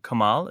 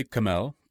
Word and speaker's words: Kamel: And they also have opened their home to Kamal. Is that right? – Technically Kamel, but yes. – Kamel. Okay. – It's more of an E-H, but in the Arabic pronunciation.